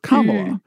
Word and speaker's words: Kamala: And I could not pronounce our new vice president's name, Kamala. Is that Kamala Harris? And she said she heard someone describe it this way, Mamala. It rhymes with Mamala. And so it's Kamala.